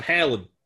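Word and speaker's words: Halan: Hey, man, it's loyal son of Aaron, son of Halen. All right. – There you go. – Son of Van Halen.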